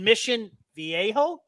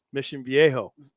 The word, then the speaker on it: Mission Viejo: Especially when you've got names like Arroyo Tribuca in Mission Viejo. – Yep, that's right. – So there's no J pronounced. – Mission Viejo.